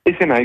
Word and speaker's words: ECMA: Euh, je l'ai bien prononcé, ECMA peut-être, c'est comme ça qu'on dit. Hein ECMA, exactement. ECMA, voilà.